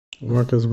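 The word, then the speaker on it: Marques: Did you just call him Mark Ass Brownlee? – Isn't that how you say his name? Mark Ass? Mark Ass? – Marcus Brownlee, yeah.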